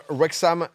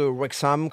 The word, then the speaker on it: Wrexham: vraiment sur une prononciation de Wrexham, comme on, peut le dire. On peut ensuite. Ouais, peut-être marquer le H, peut-être. Oh, Wrexham.